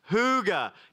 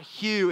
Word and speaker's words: hygge: Hygge. No, it's not hue. It's who. Hygge. Everybody say hygge. Hygge is, Jens is so proud.